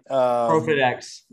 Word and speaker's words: ProFitX: He is the founder and CEO of ProFitX, if I'm saying it right. – ProFitX.